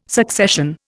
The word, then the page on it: succession: Indian